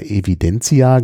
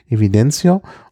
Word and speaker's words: Evidencia: Die haben zwar immer Evidencia gesagt, aber auch wenn man das hinten mit A schreibt, wird das trotzdem aus O ausgesprochen, weil es provenzalisch ist, occitanisch, Evidencio. Und auch ein sehr, sehr schöner Weißwein, sehr harmonisch.